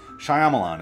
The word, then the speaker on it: Shyamalan: And stop pronouncing his name as Shyamalama Ding Dong. It's getting fucking old. Really fucking old. Shyamalan, it's not that fucking hard.